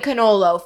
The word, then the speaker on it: cannolo: But let me say it right, it's a giant cannolo filled with teeny cannoli. Yeah, because the I makes a plural.